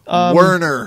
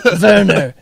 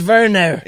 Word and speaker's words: Werner: Werner Herzog, motherfucker. – Werner. It's Werner.